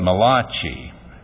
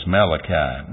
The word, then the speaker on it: Malachi: Some people call it Malachi, but since that would be a good name for a spaghetti parlor, but not a book of the Bible, we'll refer to the Hebrew pronunciation as Malachi.